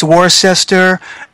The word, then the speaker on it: Worcester: and they do not know how to pronounce Worcester. – Yeah, it ends up being Worcester. – It's Worcester, and if I had hair, I'd be tearing it out by the roots.